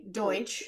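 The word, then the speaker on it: Deutch: Yeah, I was going to ask you, because it's Zoe Deutsch is what I would say.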